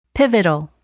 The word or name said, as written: pivotal